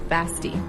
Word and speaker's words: Basti: Bassett. – No, Basti. – Basti. – Like bestie, but like...